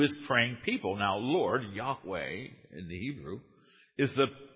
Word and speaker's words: Yahweh: We pronounce it as Yahweh.